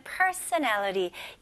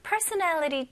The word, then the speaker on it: personality: 요새 그런 personality 테스트도 되게 많이 하잖아요. 네, personality. 이거는 성격이고요. 발음이 연습부터 해볼게요. 자, 잘 들어보세요. Personality. Personality. 네, 강세가 어디 들어가죠? 네, nal에 들어가요.